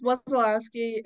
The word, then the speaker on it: Wesolowski: Give us the real pronunciation and introduce yourself to everybody. – It's Wesolowski, and I've never heard that pronunciation before.